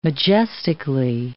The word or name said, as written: majestically